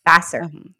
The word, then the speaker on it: Vasseur: So originally the Alfa Romeo team principal I always feel like I butcher his name, but Vasseur? – Yes. – Vasseur. – Fred. Yeah. I think it's Fred Vasseur.